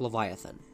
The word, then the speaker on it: Livyatan: This one went under the spelling L I V Y A T A N, and even though it's spelled differently, you still pronounce it like Leviathan.